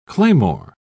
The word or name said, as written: claymore